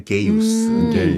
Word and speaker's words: Gaius: 네, 가이우스가 아니라 게이우스.